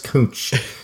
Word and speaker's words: Kunc: Aljaz Cooch. – is that a couch in the Pacific Northwest? Or no, it's K-U-N-C. I want to pronounce that correctly for everyone. K-U-N-C. Aljaz, A-L-J-A-Z, Aljaz Kunch.